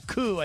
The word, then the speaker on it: coup: Actually, coup, I think is how you pronounce it.